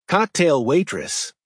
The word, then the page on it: cocktail waitress: American English